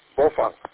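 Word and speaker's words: Baofeng: And I don't know if you guys realize, not that it's a big news or anything majorly to talk about, but everybody calls it Bofang, Bofang, Bofang. And it's actually pronounced Bofang.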